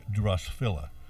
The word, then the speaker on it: Drosophila: It could be the larva of the spotted wing Drosophila. – Oh, for heaven's sakes. – That's what and that's a worm that seems to like strawberries. – Rhymes with Godzilla. – G-R-O-S-P-H-I-L-A.